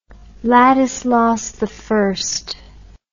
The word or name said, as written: Ladislaus I